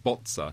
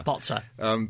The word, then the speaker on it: bozza: And it comes actually under his definition of botch, to be absolutely fair, because his definition of botch then gives the Italian word Buzzer, pronounced bozza. – Botza.